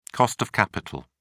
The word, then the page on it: cost of capital: British English